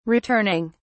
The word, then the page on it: returning: British English